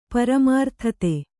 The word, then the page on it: ಪರಮಾರ್ಥತೆ: kannada